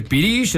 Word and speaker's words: pirix: Y ¿cómo se escribe? – Pirix. – Pirix. – O sea, pirish. Se dice pirish, es una palabra muy hermosa.